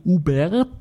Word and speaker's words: Hubert: Es wurde zum Beispiel aus Frankreich ein Internetbeauftragter geschickt, also von der Regierung Sarkozy, der Jean-Michel Hubert, das H wird ja nicht ausgesprochen, Hubert zu Deutsch, ich werde den wahrscheinlich auch Hubert jetzt nennen, ist tut ja nichts zur Sache.